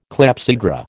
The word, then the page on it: clepsydra: American English